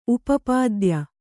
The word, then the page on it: ಉಪಪಾದ್ಯ: Kannada